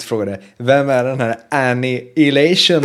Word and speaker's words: Annihilation: Som vi också har fått skit för hur vi uttalar. – Ja, jag vet. Annihilation. Så säger man ju inte.